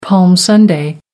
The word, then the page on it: palm sunday: American English